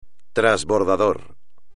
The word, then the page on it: trasbordador: espagnol